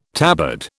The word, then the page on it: tabard: British English